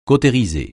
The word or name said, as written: cautériser